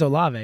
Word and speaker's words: Olave: Is it Olave? – I think it's Olave, yeah.